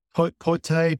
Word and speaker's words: Paiute: and I'm going to mispronounce it, Poite, P-A-I-U-T-E reservation.